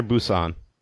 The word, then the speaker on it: Busan: Is it Busan or Busan? – So the Korean pronunciation is Busan, but okay. – It used to be always translated as like Busan, which I go with like a P, but they've since gone back and made it a B.